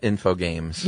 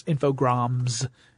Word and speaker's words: Infogrames: And not Infogames. – Yeah, I keep thinking it's Infogames. It's Infogrames. Because it's spelled like Infogames, but with an extra R there, right after the G.